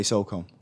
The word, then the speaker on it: SOCOM: How do you pronounce... the Navy SEALs game from Zipper Interactive. – SNOM? – SOCOM. – SOCOM. – Is that, you say SOCOM? – Yeah. – What about you? – SOCOM.